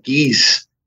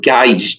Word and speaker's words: Guise: Prior to her was her mother, and we call her Mary of Guise. It looks like Guise, G-U-I-S-E. So most English speakers say Mary of Guise, but Mary of Guise is the... Well, I was taught that's the proper pronunciation, so I just do what I'm told, you know?